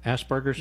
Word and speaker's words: Asperger's: There's a condition called, is it Asperger's? Am I pronouncing that correctly? Asperger's, yes.